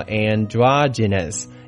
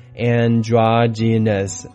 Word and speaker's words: androgynous: 这个词是今天可能学到的最难的一个字啊，它的发音叫做 androgynous, androgynous, A-N-D-R-O-G-Y-N-O-U-S, A-N-D-R-O-G-Y-N-O-U-S, androgynous, androgynous, 重音在第二个音节 dra, androgynous.